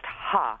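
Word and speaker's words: ha (ห้า): I was really excited by Michael from Bangkok noting that in Thai, the number 5 is pronounced ha,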